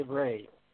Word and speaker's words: Rie: So, Dr. Van Rie, I asked my Dutch wife, is your last name pronounced Rie or Ray? – Well, she wasn't sure. – Say Ray.